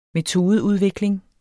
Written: metodeudvikling

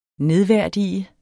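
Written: nedværdigede

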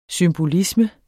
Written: symbolisme

